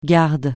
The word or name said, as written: garde